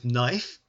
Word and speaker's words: knife: Life rhymes with knife, but we don't say the K.